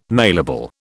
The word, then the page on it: mailable: British English